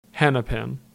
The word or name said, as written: Hennepin